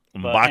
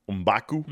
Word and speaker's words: M'Baku: M'Baku. Anyway. M'Baku. – M'Baku.